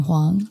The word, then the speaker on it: Hwang: My Facebook is my full Korean name, Jay Yoon Hwang. – Hwang. It's like a gong.